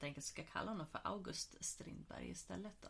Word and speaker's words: August: Men August kommer från Augustus och ska uttalas som Augusti.